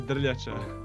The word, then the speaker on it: Drljaca: Uh, uh my surname is Drljaca.